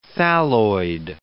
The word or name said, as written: thalloid